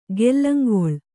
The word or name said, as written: ಗೆಲ್ಲಂಗೊಳ್